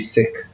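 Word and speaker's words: Sikh: So you've been saying sick and I've been saying seek. And so. – Yeah, it's if a pronunciation traditionally will be sick. – Okay. – Yeah, because, you know, but, you know, an anglicized term normally is, you know, we say sick. I say it sometimes too, but, you know, sick is, you know, so you call yourself a sick. It's how you, you know, pronounce it in, you know, in good, you know, the language.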